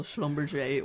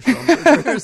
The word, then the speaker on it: Schlumberger: Schlumberger, I always thought Schlumberger. – Well, it's pronounced in a kind of French way as well, Schlumberger or Schlumberger, but most people I've heard are saying Schlumberger. So, yeah.